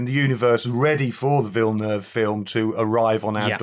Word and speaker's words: Villeneuve: Villeneuve film.